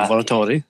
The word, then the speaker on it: lavoratori: You can check my Italian pronunciation here, because I know you speak the language. Lavoratori impatriati. – Lavoratori, sì.